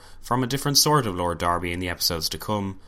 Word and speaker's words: Derby: Lord Derby, as I've been pronouncing it, is actually Lord Darby, and will be referring to him as such from now on, just so you know.